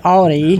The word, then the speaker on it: Audi: And, of course, it's spelled A-U-D-I, so people might want to say Audi. – Right. – Mm-hmm. But it is Audi. And one of the reasons it's pronounced is if you add an O to the end, you get a word very recognizable to English speakers.